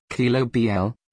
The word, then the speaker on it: Quilombola: A Quilombola, Portuguese pronunciation, Quilombola, is an Afro-Brazilian resident of Quilombo settlements first established by escaped slaves in Brazil.